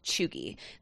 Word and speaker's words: cheugy: It's spelled C-H-E-U-G-Y, pronounced chuggy.